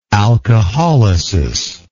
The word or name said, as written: alcoholysis